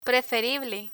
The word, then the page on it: preferible: Spanish